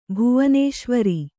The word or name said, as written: ಭುವನೇಶ್ವರಿ